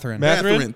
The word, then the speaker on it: Mathurin: Am I saying it right? The rookie on the Pacers, Mathurin. – Mathurin, thank you.